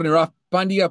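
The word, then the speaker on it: Nirav Pandya: Bueno, muchas gracias, doctor Niraf Pandya. Pueden seguirlo en su cuenta en Twitter, arroba DR, doctor. Niraf se escribe N-I-R-A-B, pequeño B chica, y Pandya se escribe P-A-N-D-Y-A.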